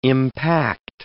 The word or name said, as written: impact